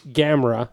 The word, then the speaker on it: Gamera: Number four would be Gamera. That's how you pronounce his name.